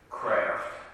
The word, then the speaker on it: Kraft: Kraft. My last name is spelled K-R-A-F-T.